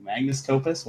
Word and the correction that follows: magnum opus: What is it? – Magnum opus? – Magnum opus. There it is.